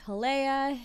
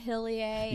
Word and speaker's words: Hillea: I'm gonna spell it Hillea. Okay. It's like Hillea, Hillea. – Yeah, really. People say it different ways.